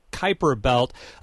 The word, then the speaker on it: Kuiper: Yeah, the Kuiper Belt, and I had to look up the pronunciation because it looks like Cooper or Kuiper Belt, but Kuiper Belt.